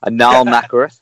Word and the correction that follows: Nathrakh: is that right? Uh, Nathrakh, I would say.